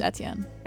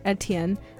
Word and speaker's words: Etienne: It's Etienne. – Etienne.